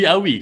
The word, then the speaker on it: Awie: How is AWIE, Awi, and then you don't pronounce the E?